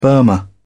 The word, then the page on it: burma: British English